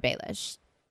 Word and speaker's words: Baelish: So I feel like, but I really don't want to have sex with Lord Baelish, right? That's how you say his name.